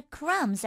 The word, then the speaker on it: crumb: مثال اول: Our crumbs are composed of only the finest ingredients. – Our crumbs. crumb، دقت کنید که b بعد از m تلفظ نمیشه.